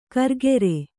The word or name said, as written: ಕರ್ಗೆರೆ